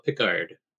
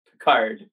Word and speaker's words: Picard: But friends of the show, they said Pickard, and the Ken Daniels called him Pickard. Hot debate there. I don't care what you call him. He's our new leader. – I'm not playing. Yeah, I mean it's classically pronounced Picard.